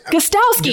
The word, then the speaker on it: Gostkowski: The vet, number three, Mr. Steven, I'm going to butcher his last name, Gutkowski is it Cal- or is it Gostkowski? – Gostkowski. – He's got it.